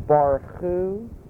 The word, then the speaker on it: Barchu: And in English, it's generally spelled B-A-R-C-H-U. This is the way you pronounce it. Baruch Hu et Adonai Hamvorach.